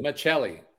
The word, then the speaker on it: Maccelli: With, Maccelli.